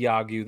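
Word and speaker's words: Yagyu (야구): This hat says Yagyu. That's how you pronounce it. It means baseball in Korean, and that's part of our KBO collection.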